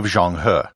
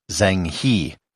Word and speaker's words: Zheng He: And here I should note the pronunciation of Zhong He. If you read the title of this episode and you speak English, you might pronounce it as Zheng He. I'm trying to stay true to the Chinese pronunciation as much as I can.